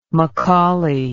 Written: mccauley

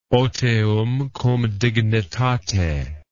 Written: otium cum dignitate

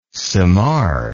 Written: simar

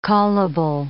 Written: callable

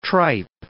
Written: tripe

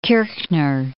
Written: Kirchner

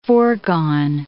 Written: foregone

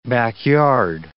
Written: backyard

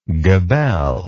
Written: gabelled

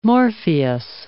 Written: Morpheus

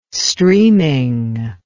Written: streaming

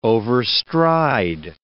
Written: overstride

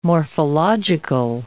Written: morphological

